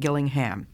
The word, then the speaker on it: Gillingham: Her name was Gillingham, spelled Gilling, G-I-L-L-I-N-G, Ham, Gillingham. Always pronounced in the English way, Gillingham.